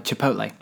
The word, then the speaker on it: Chipotle: you mentioned the word Chipotle there, which is the correct pronunciation, of course.